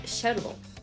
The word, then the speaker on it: Shoutable: And in part two, Rue's interview with Travis Morrison of Shoutable. – Very good. You pronounce it without the E.